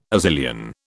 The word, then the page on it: Azilian: British English